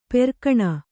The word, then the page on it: ಪೆರ್ಕಣ: perkaṇa